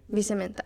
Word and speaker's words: Vicementa: Vicementa, right? That's how you say it? – Vicementa. – Vicementa, yeah, all right.